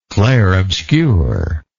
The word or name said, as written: clairobscure